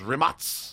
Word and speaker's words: Rimac: Have you heard Rimac? But it's Rimac. – Actually pronounced Rimac. – Rimac, okay. – It's Croatian.